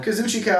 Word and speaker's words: Kazuchika: The Painmaker, Chris Jericho, versus the Rainmaker, Kazuchika Okada? Did I say it right? – Kazuchika.